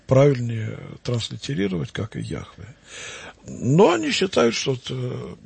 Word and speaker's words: Яхве: И в XIX веке почти все ученые считали, что надо произносить это имя как Йегова. Мы знаем, что некоторые святые отцы произносили так это имя, Феофан Затворник. В толковой Библии Лопухина сплошь и рядом мы видим Иегова, Иегова, Иегова. В комментариях, которые были сделаны в конце 19-го, начало 20 века на весь текст книг Библии. Но уже сейчас в библеистике общепринятой транслитерации этого имени считается имя Яхве.